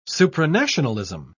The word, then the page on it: supranationalism: British English